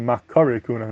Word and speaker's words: מכירי כהונה: he points out that that's not really the proper pronunciation. He was a big Gvali Gabal Medaktik, a big Bakian Diktik, and he says that the proper pronunciation should really be Makari Kohuna, not Makiri Kohuna, with a patach, based on the Pasuk.